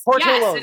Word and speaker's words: Portillo's: To Petrillo's. Is Petrillo's still there? – Oh, yes. Portillo's. Yes. – Portillo's. Yes, thank you.